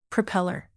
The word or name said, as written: propeller